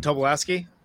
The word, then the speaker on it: Tobolowsky: Steven Tobolowsky? – Yes.